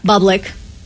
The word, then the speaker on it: Bublik: I think it's Bublik. – Bublik. Like public.